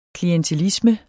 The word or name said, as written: klientelisme